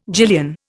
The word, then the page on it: gillion: Indian